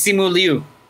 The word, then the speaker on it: Simu Liu: I don't want to say Simu. It's not Simu, is it? Oh yeah, it's it's Simu Liu.